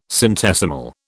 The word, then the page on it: centesimal: British English